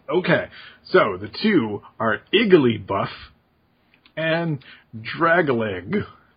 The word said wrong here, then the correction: Dragalge: So I'll complete it as Dragalge.